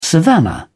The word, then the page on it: savannah: British English